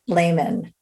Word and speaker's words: Leamen: I am so excited to talk today with Juliana Leamen.